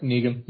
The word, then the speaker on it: Negan: Yep, Negan.